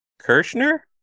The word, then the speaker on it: Kreischer: Burt Kreischer? – Kreischer. – Sorry, I butcher his name.